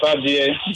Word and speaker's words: Abie: Joseph Abie. – Joseph Abie. How do I spell that? – A-B-I-E. – A-B-I-E.